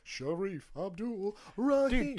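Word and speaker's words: Shareef Abdur-Rahim: Sharif Abdul Rahim.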